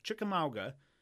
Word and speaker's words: Chickamauga: Battle of Chickamauga. I'm pronouncing that correct. – Oh, you sure? You nailed it.